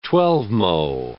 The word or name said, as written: twelvemo